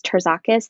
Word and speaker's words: Terzakis: My last name is Terzakis.